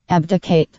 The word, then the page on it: abdicate: American English